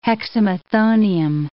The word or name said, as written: hexamethonium